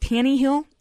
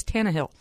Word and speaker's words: Tannehill: So, and people are mispronouncing Tannehill. It's not Tannehill, like the former South Carolina quarterback. It's Tannehill.